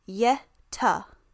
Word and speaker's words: Yehta: And the word Yeti is believed to be a mispronunciation of the Tibetan word Yehta, which is Y-E-H-T-A-H. So two words.